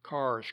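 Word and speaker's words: Kerr's: but soon acquired the name of Carr's Creek. That's spelled K-E-R-R apostrophe S, but pronounced Carr.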